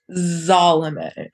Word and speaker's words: Salome: Zalome. – Oh, Zalome. Okay. It's spelled with an S, yes, but like, Zalome.